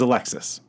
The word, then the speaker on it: Alexis: Like I had this student named Alexius. – Alexius. – No, it was Alexis.